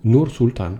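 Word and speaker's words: Nur-Sultan: Så øh, fremover, så skal man så lære at udtale ordet Nusultan. Jeg er ikke engang sikker på, at jeg udtaler det rigtigt. – Og det er jo så... Nu, nu, Nur Sultan, jo.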